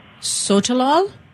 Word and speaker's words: Sotalol: Let me see if I can spell it for you. It's S-O-T-A-L-O-L. – Sotalol? – Well, is that how you say Sotalol?